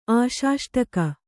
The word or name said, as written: ಆಶಾಷ್ಟಕ